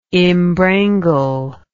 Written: imbrangle

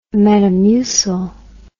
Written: metamucil

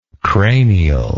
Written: cranial

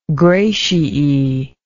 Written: gratiae

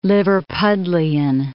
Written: Liverpudlian

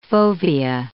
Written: fovea

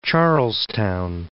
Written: Charlestown

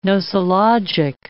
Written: nosologic

